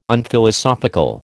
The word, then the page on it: Unphilosophical: American English